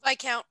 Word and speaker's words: Viscount: Viscount. – Viscount. Sorry. There's an S, but it's we don't say it. – That's another French word that they decided to actually keep this time. – Viscount de Chagny.